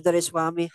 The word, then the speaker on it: Doraiswamy: If it's hard to pronounce my last name, just make it simple, three syllable. Dor, Rice, and Wami. Put that together and you have Doraiswamy. That's how you pronounce it.